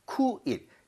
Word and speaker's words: quit: 그래서 U하고 I가 동시에 있다 한다라고 U, E 합치면 위지 그러니까 퀴시지 이렇게 하시면 안 돼요. 쿠잇입니다. 쿠잇.